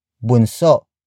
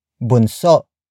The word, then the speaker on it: bunso: If you are the baby in the family, you can say bunso. Bunso. Bunso. Bunso is spelled as B-U-N-S-O.